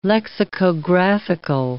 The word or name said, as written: lexicographical